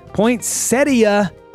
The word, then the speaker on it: Poinsettia: About 60 million are grown in North America each year, although proper pronunciation of the word does include the E around sound prior to the last syllable. So I said it correctly. Yes! Poinsettia, I said it.